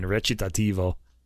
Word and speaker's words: recitativo: I just know in Italian, recitativo.